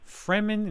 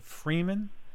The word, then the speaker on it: Fremen: Fremen instead of Freeman. You know, Freeman, people who are free.